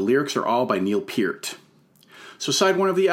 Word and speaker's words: Peart: Alex Lifeson on electric and acoustic guitars, and Neil Peart, and that is how you pronounce it, on drums and percussion.